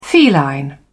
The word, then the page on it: feline: British English